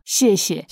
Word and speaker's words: Xie xie: Si tuvieses que empezar conmigo, por ejemplo, que no sé nada, solo sé decir chie chie, y por ahí lo pronuncio mal, que es gracias, ¿no? – Xie xie.